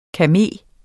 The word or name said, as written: KAME